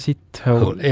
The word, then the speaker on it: Sithole: Jaja Sithole. – Jah. – Kuidas niimoodi hääldataksegi? – No, kirjutatakse S-I-T ja hole, nagu ingliskeele samas.